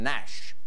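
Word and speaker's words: gnash: What is G? – G in Nash.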